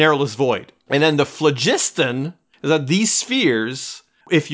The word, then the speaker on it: phlogiston: Phlogiston. If you tell me it's phlogiston, I'm going to go with that. – Soft G in front of an I, phlogiston.